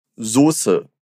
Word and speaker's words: Soße: E a palavra molho em alemão, ela pode ser escrita de dois jeitos diferentes, mas a pronúncia é igual. Repete comigo. Soße.